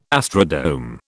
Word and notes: astrodome: British English

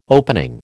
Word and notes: opening: American English

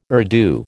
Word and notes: Urdu: American English

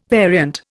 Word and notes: variant: Indian

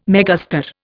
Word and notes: megastar: Indian

